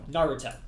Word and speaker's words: Naruto: Naruto. – Gotcha. First syllable.